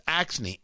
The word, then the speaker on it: Axne: In the latest of this, Iowa Democratic Representative Cindy, I think it's pronounced Axne, A-X-N-E.